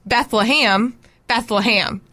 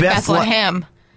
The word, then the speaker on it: Bethlehem: But you cannot pronounce Beth-LUH-HEM. You have to pronounce it Bethlehem.